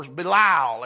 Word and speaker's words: Belial: We read in the Old Testament, sons of Belial, or Belial, as sometimes we pronounce it.